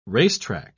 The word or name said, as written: racetrack